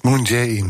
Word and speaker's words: Moon Jae-in: De Zuid-Koreaanse president Moon Jae-in, zeg ik het goed? Moon Jae-in.